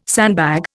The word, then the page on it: sandbag: Indian